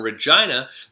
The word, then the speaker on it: Regina: and he's half Canadian because his mom is from Regina, the city that rhymes with fun.